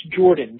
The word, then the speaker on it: Jordan: L-O-N-N-I-E. – Okay, great. And Lonnie, what is your last name as well? – It's Jordan, J-O-R-D-A-N.